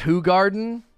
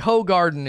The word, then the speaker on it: Hoegaarden: It's pronounced Hoegaarden. I think Hoegaarden is the correct pronunciation. A garden of hoes.